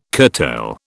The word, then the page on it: curtail: British English